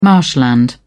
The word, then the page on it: marshland: British English